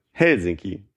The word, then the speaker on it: Helsinki: Aber äh, also in Finnischen wird auf der ersten Silbe betont. Helsinki.